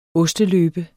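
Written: OSTELØBEN

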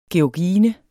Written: georgine

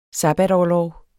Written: sabbatorlov

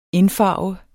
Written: indfarve

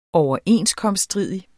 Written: overenskomststridig

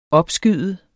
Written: opskyde

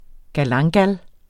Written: galanga

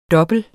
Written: double

